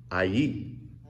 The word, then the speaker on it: Ai: Now, it's spelled A-I, and I Googled how you pronounce it, and you pronounce it I-E. Yeah, but that sounds weird, so I'm not going to say it that way.